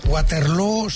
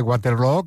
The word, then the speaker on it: Waterloo: Pues no lo es, desde luego, pero ha hecho mucho daño en la pronunciación de Waterloo, que se pronuncia igual que Feijó.